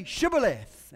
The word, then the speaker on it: Shibboleth: And when he says no, they say to him, "Well then say Shibboleth." And he says, "Sibboleth." Can't pronounce it.